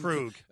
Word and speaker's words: Krug: Krug.